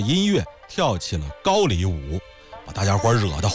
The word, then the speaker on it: 高丽: 还有一回啊，武则天的另外一个男宠张易之，张易之的兄长叫张同修，张同修在宴会上就拿这个杨再思啊开涮寻开心，说杨公的脸好像高丽人。我知道这个词儿正经的读音应该是读成高丽，也就是朝鲜历史上那个王朝啊。